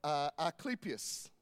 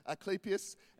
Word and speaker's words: Asclepius: the god Asclepius. Okay, that's exactly how you say it, Asclepius.